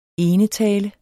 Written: enetale

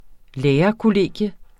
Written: lærerkollegium

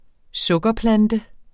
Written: stevia

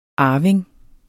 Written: arving

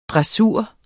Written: DRESSUREN

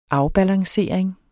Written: afbalancering

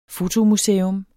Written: fotomuseum

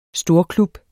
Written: STORKLUB

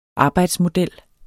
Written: arbejdsmodel

